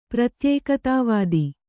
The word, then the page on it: ಪ್ರತ್ಯೇಕತಾವಾದಿ: pratyēkatā vādi